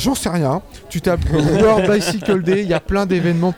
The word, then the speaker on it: World Bicycle Day: Et puis le lundi 3 juin, voilà un événement international, ça sera la deuxième édition du World Bicycle Day. Je le dis bien, non ? Yes. Je prononce bien ? – C'est pas mal.